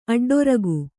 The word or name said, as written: ಅಡ್ಡೊರಗು